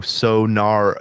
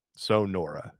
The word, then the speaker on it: Sonora: Do you mean Sonora? – No, Sonora is how you say it. Sonora.